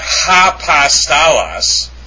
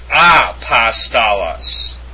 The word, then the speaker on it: apostolos: Now, it's not apostolos. Do you know why? Because the accent is on the first omicron.